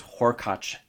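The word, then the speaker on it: Hurkacz: As far as we can tell, that is how he says it himself, according to the ATP website anyway. It's a weird kind of inconsistent standard that commentators and faux commentators like ourselves apply, where sometimes we try to say it correctly, sometimes we do some anglicized version, but as far as today goes, Horkacz it is.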